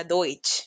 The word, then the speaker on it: Deutsch: Entonces, partamos, por ejemplo, alemán es, se escribe Deutsch, así se escribe, pero se pronuncia Deutsch.